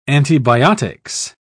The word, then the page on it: antibiotics: British English